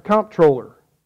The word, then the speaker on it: comptroller: It's controller, but we would pronounce it comptroller. It's actually from a French word that means account or accountant, I think.